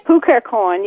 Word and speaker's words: Cukierkorn: Cukierkorn, yes.